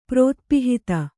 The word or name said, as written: ಪ್ರೋತ್ಪಿಹಿತ